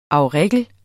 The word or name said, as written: Primula auricula